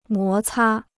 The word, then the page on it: 摩擦: mó cā